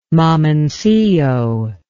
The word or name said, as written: mamoncillo